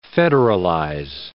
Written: federalize

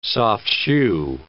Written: soft-shoe

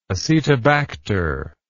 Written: acetobacter

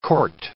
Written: court

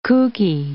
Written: kooky

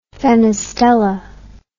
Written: fenestella

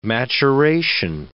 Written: maturation

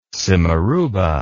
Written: simarouba

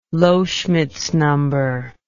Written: Loschmidt's number

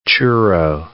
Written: churro

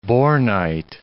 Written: bornite

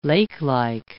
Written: lakelike

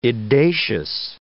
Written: edacious

